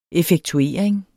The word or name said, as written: effektuering